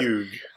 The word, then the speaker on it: Fugue: Fugue.